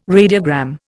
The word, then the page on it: radiogram: Indian